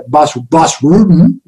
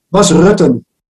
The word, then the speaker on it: Bas Rutten: And, Bas Rutte was also really, really good. Bas Rutten, as you would say. – Say his name? – Bas Rutten.